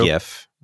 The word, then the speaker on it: GIF: I say GIF. – Me too. – GIF, hard G, GIF support in there, which is cool.